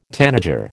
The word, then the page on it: tanager: American English